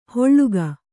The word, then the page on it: ಹೊಳ್ಳುಗ: holḷuga